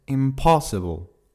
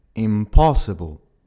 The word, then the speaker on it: impossible: Encore un mot transparent en anglais qui s'écrira donc de la même façon qu'en français, mais qui se prononcera différemment, puisqu'il se prononce impossible. Tu entends bien que l'accentuation se fait sur la deuxième syllabe, impossible.